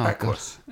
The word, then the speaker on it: Equus: Equius. Harry Potter. I nearly wrote Harry Potter cock then. Harry Potter horse play. What was it? – Oh, Equus.